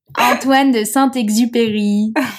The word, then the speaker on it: Antoine de Saint-Exupéry: Antoine de Saint-Exupéry. I will say it with an American accent, but he is... – No, how do you say it in the French accent? – Antoine de Saint-Exupéry. We did visit outside his home the other day.